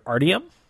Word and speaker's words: Artyom: It's in the perspective of the character Artyom, A-R-T-Y-O-M, Artyom. Artyom. They kind of pronounce it weird in their Russian accents, but it's Artyom.